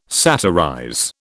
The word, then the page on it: satirize: British English